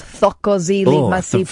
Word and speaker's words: Thokozile Masipa: Thokozile Masipa... – What did you say? – That's what her name was, Thokozile Masipa. – For the first syllable there, I thought, wowzers, lost the plot.